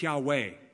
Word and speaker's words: Yahweh: We say Jehovah in English. It was probably pronounced Yahweh by the Hebrews.